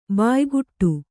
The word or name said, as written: ಬಾಯ್ಗೂಡು